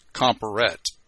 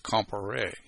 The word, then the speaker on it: Comparet: So if you look at the way it's spelled, you think in English it's Comparet, because it's how we pronounce things that end in E-T in English. However, in French, the E-T on the end of a word is pronounced A, so it's Comparé.